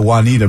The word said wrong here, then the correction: Juniata: my first telecast. – Juniata, the college? – Well, spelled like Juanita.